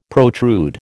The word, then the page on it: protrude: American English